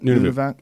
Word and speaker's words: Nunavut: Yeah, Steve Ranella went to Nunavak. Is that it? – Nunavut. Yeah, something like that.